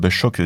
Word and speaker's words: Sjock: Donc Choc, déjà ça s'écrit S-J-O-C-K, autant le savoir,